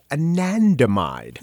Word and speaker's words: anandamide: It is anandamide.